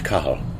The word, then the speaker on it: Cathal: No, you don't pronounce the T. – Oh, okay. – It's C-A-T-H-A-L. Or in some parts of Ireland, C-A-T-H-A-L. – That's right. Yeah, the Catholics say H, right? – That's right. That's right. And it's pronounced Cahal. – Cahal. Okay. – So it's a silent T,